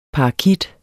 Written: parakit

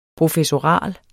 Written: professoral

